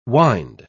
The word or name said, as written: wynd